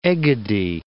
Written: Egadi Islands

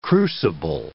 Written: crucible

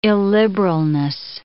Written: illiberalness

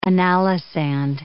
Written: analysand

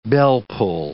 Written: bellpull

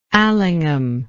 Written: Allingham

